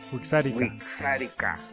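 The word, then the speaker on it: Wixárika: W-I-X-A-R-K-A. – Huitzárika.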